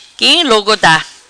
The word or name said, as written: Quim Log Da